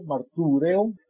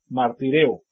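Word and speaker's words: Martireo (μαρτυρέω): Hoy en día, el griego moderno utiliza esa U que está en el medio, martureo. Esta es una M, M-A-R-T-U-R-E-O. Esta es U, se pronuncia como una I hoy en día. Martireo.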